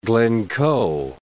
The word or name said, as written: Glencoe